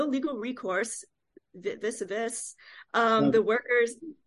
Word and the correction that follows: vis-a-vis: That's pronounced vis-a-vis. – Vis-a-vis. – It's French. Vis-a-vis.